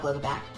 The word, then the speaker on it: Glogovac: Beyond the Campaign, and her name is Michelle, and I'm going to spell this for everybody, GLOGOVAC. So Glogovac, but she pronounced it so quick, that's probably because she's used to it.